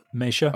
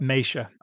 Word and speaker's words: Mesha: King Mesha, or Mesha, how would you say it? – Mesha. – Of Moab? Mesha? – Mesha. – Mesha, okay.